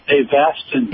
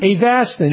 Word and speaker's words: Avastin: The drug you're talking about is pronounced Avastin. – Avastin, yes. – Avastin, and that's how it's been talked about. That's how you pronounce it correctly.